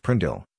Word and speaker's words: PRNDL: The gear selector is often called the PRNDL, pronounced Prindle, regardless of the actual letters or numbers used.